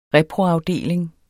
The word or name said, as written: reproafdeling